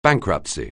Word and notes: bankruptcy: anglais

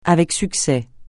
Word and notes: avec succès: francais

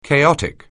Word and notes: chaotic: anglais

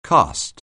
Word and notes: caste: anglais

